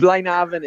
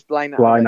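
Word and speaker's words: Blaenavon: Is it Blaenhaven? I live absolutely live in Wales myself, and I always struggle to pronounce Welsh words. – Blaenhaven, it is. It's Blaenhaven. Yeah, Blaenhaven, Wales. You gotta use that Welsh voice you have and make it sound as Welsh as possible.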